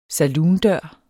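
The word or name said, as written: SALOONDØREN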